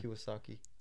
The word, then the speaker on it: Kiyosaki: Kiyosaki.